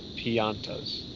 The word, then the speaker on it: Piantas: I said Piantas. – That's correct. – Everyone I know says piantas. – It's not pianta, it's pianta. It's a Italian word.